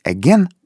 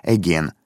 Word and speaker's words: again: Кстати, имей в виду, словечко again, снова, опять, можно произносить again, а можно again. И то, и другое правильно. Но в нашей считалке мы для рифмы произносим again.